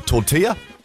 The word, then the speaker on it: tortilla: Now, I've been just been texted through here saying, please pronounce tortilla correctly. So it's apparently, according to this text, tortilla.